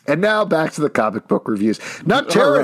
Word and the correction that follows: Nocterra: Did you say Nutterra? Don't pronounce it weird. – Nocterra.